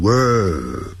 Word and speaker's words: World: 世界那个字叫 World, 因为有一个 R, 还有一个 L, 所以先念成卷舌音 World, 然后那个 L 呢，翻成欧洲的 O, World, 就 World, 好，那个 D 念得很弱，好 ,World, World War II, 啊，就是第二次世界大战。